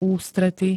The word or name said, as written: ústrety